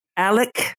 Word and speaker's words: Alex: Since 2014, Alec, also called Alex, it's spelled Alex, it's said predominantly Alec, I think we'll use.